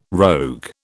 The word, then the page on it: rogue: British English